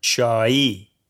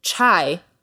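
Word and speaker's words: chai (चाय): Chai. – Exactly. It's pronounced differently than the way we say it in the English language, chai. It's chai.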